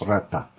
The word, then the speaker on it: raat: Rata is spelled R-A-A-T. So you're pronouncing the ra and then also the T, you know, on the end. So it's rata.